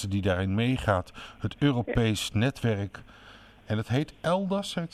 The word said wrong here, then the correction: eIDAS: zeg ik het goed? – Nou, het heet EIDAS. – EIDAS, oh, dat is EIDAS, ja.